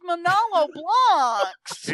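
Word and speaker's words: Manolo Blahnik: Curse Milano or whatever those. – Oh my gosh, you can't even pronounce it. It's Manolo Blahnks.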